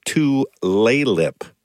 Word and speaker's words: Tulalip: Tulalip.